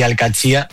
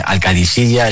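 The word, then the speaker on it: Al-Qadsiah: Bueno, se suele decir Alcachía, pero también hay gente que dice Alcadisilla. Es que es difícil, ¿eh? No es fácil, pero Alcachía es lo más fácil.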